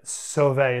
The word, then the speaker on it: Sauve: Brian Sauvay. It's spelled S-A-U-V-E on the Twitter.